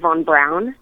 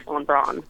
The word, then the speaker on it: Von Braun: uh, you can say it either way. Von, it's technically Von Braun. Von Braun. Like okay. color, but everybody says Von Braun, so that's okay.